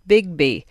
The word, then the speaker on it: BGBY: The Big Green Big Year has the acronym BGBY and is therefore pronounced Bigby, and it is simply a big year in which you only count species seen within walking or cycling distance of your home.